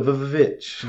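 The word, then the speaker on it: VVitch: I believe it's the Vitch. – Yeah, the Vitch. Yeah, I wasn't sure if I had to pronounce the V's. – No, you're good.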